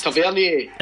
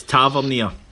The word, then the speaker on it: Tavernier: He said your pronunciation of Tavernier drives him nuts. – Okay. – It's Tavernier. – It's Tavernier. – Tavernier, it's a French word.